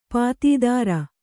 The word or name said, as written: ಪತ್ತೆದಾರ